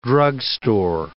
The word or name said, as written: drugstore